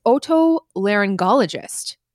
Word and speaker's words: otolaryngologist: It is otolaryngologist. – Otolaryngologist. – That's, yeah. So I wasn't sure if it was like a hard G in the first G, but it is otolaryngologist, unless the pronouncer videos have lied to me, which is possible.